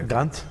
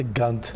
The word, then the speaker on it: Ghent: I think you're pronouncing it correctly, actually. – Ghent? – Yeah. – Yeah. If you went to Ghent.